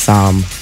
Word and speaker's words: Psalm: Psalm. – Psalm? – With a P? Silent P, silent L. Psalm. – Wait, P, silent L would be Sam. – No, but they said it's pronounced Psalm. I'm just telling you.